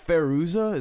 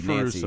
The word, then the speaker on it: Fairuza: Is it Feruza? Is that how you say her name? – Her real name? – F-A-I-R-U-Z-A. Nancy Downs was her name in the movie. – Feruza, Nancy.